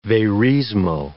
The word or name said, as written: verismo